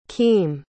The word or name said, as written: keme